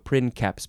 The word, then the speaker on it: princeps: And then in Latin, that's why it's pronounced that way. Editio princeps, because the C has a K sound.